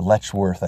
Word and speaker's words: Letchworth: Being able to see, and depending on, like, your late September, October, whatever, the trees in Letchworth State Park, or Letchworth, I think is how you say it correctly, they turn orange, green, yellows, browns.